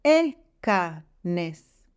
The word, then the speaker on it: אכנס: The only exception is אני אכנס. I demonstrated to you the ea, but the accent of the word goes on the last syllable.